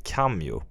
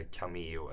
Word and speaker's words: Kameo: Det finns ett företag som heter Cameo. – Mm. – eller Cameo, eller ja, K-A-M-E-O, valfritt uttal.